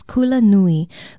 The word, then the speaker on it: kula nui: Aloha, I'm Leilani Poliahu from Hawaii Public Radio, and our Hawaiian word of the day is kula nui. Kula nui is spelled K-U-L-A-N-U-I, and it literally means big school.